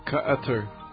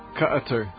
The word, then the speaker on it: Qatar: You know, for a two-syllable country of five letters, nobody knows how to pronounce it. – Qatar. – What? Wait, say that again? – Qatar. – You somehow made it three syllables. How did you do that? – It's Qatar.